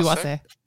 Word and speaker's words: wase: Is it wase or is it wase? Wasp or... Yeah, it'd be wase. – They don't believe in silent letters.